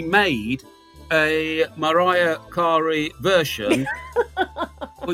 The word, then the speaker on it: Mariah: She's Mariah, right. By the way. We ought to pronounce her name properly. – All right. – Yeah. – All right. Thank you, Miss Jones. Yeah. Thank you. – That's all right. – In an English pronunciation, Mariah Carey.